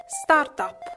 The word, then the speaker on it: startup: startup letteralmente significa avvio e, per la cronaca, l'esatta pronuncia inglese è con l'accento sulla A, startup.